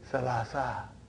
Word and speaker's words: ثلاثة: So that is why you have salasatun. But in pronunciation we will say salasa.